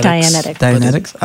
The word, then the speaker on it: Dianetics: What's Diantics? – I don't know what it is. Phil was explaining it. To me, it's antics that Diane does. – He's talking. – I think it's pronounced Dianetics. – Dianetics.